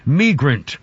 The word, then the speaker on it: migrant: And by the way, about a minute into this comment, she's going to say the word migrant, which she pronounces migrant, and it sounds like she's saying Negro. She's not saying Negro. She's saying a migrant.